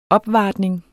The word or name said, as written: opvartning